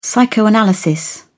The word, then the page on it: psychoanalysis: British English